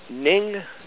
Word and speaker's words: Ning: And before I actually ask the question, share with everyone on the call about the Ning Network, what it is, what they can do with it, because this is such an important new asset that you've brought to fold here. – The Ning Network. – We better spell that, by the way. – N-I-N-G.